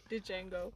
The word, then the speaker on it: Django: No, seriously, it was like when that movie Django came out, and I was just that one black person who refused that D is silent. – Django.